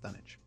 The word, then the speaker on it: Dunwich: Chad, I th- it's it's pronounced Dunwich. – What, where's the is the silent W? – No, it's like Greenwich.